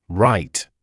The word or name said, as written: write